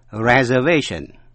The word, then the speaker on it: reservation: Cũng để ý đến cách phát âm động từ to reserve, tức là dành chỗ, to reserve, nhưng mà danh từ reservation. Quý vị nghe lại, to reserve, reservation.